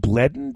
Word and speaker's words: Bleddyn: there was one from, I think it's pronounced Bledden Davies, B-L-E-D-D-Y-N, from the UK.